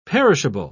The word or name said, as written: perishable